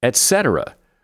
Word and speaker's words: etc.: Etc. Pronounce etc. exactly how it is spelled. The first syllable is et, not ek.